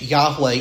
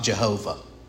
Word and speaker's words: Yahweh: And just so we're all on the same page, Yahweh is the pronunciation, not Jehovah.